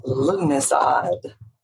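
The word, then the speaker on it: Lughnasadh: It's pronounced Lunisod. Or Lammas for short. – Oh my goodness gracious. Yeah, but if you saw how it was spelled, it was like, it's like trying to pronounce a city in Massachusetts. It just, there's letters there that aren't being used.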